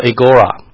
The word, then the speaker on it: agora: 然后第二个，你发觉他会到世上，这个世这个字，希腊文的发音叫做 agora。我后来去 Google Map 的时候去了雅典，雅典它的古城，它的 old town，它的名字就叫做 agora， A-G-O-R-A，这是当时最重要的所谓商贾聚集的中心，市场市集的地方。